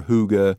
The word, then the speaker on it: hygge: It's H-Y-G-G-E, which is why a lot of people will say hygge or hygge or hygge, but it's hygge.